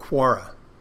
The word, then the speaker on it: Quora: And whenever, you know, it's funny, I get these emails from this site called Quora. And I think that's the correct way to pronounce it.